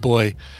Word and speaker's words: buoy: So Zoe confirmed that it's pronounced boy in the UK.